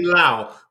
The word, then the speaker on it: Lau: It's William Lau, which rhymes with Leslie Chow.